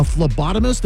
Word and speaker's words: phlebotomist: You're one of three laboratory technicians, a phlebotomist. Am I pronouncing that correctly? – That is correct.